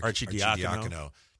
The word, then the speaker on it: Arcidiacono: I'm sorry, Arcidiacono.